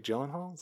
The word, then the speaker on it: Gyllenhaal: Jake Gyllenhaal? Is that how you say it? Gyllenhaal? – I think that's how you say it.